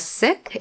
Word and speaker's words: Sikh: I am a Sikh. It's spelled S-I-K-H, pronounced Sikh, not Sikh.